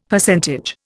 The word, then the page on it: Percentage: Indian